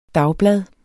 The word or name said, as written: dagblad